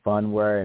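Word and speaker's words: Phunware: So it was another one called Funware, which is spelled P-H-U-N, Funware, and it went up around the same time.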